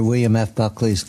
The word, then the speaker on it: William F. Buckley's: That would be not permitted under William F. Buckley's guidance.